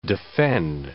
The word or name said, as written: defend